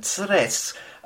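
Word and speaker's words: Krk: A very long, thin and beautiful place called Kres, as it's written down, and Tres, as it pronounced approximately it's the nearest city is Rijeka,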